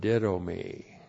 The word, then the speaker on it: didomi: And then we have the verb didomi. D-I-D long O-M-I. Didomi.